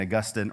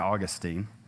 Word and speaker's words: Augustine: you pronounce it St. Augustine or St. Augustine.